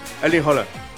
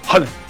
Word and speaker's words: Erling Haaland: Bueno, Alfredo González, comentarista de Fórmula Deportes, ¿cómo se pronuncia? – Holland. – Es con O. – Y es rápido, es Erling Haaland. – Haaland. – Así es su nombre.